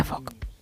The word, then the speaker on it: folk: Su regla clave es que la letra L es muda. – Anda. – Con lo cual se pronuncia folk.